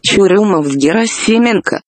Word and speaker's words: Churyumov-Gerasimenko: The European Space Agency blog has the pronunciation, so let's just let them say it. – Churyumov-Gerasimenko. – So that's how it's actually pronounced, much better than my pronunciation.